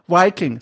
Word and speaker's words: Viking: Und zwar heißt dieses Spiel Wiking City Builder. Oh Gott, ich hab's falsch ausgesprochen. Viking City Builder.